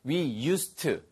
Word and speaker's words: used to: 발음 하나만 주의하세요. We used가 아니고요. We used to. 그래서 뒤에 드 발음이 나지 않습니다.